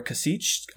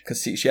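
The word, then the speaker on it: Kasischke: So, these are some excerpts from a poem called Warehouse of Prayers by Laura Kasich, I think is how you would pronounce her last name. It's K-A-S-I-S-C-H-K-E. It's one of those fun names. Kasich, yeah, that's what I think, too.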